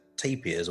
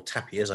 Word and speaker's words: tapir: Although, I mean, getting back, if I may, to tapirs, or tapirs, I believe we pronounce that way as well, I would love a tapir.